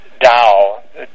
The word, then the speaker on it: Tao: The sense of Tao, D-T-A-O, sometimes D-A-O, it's pronounced as a D in any case,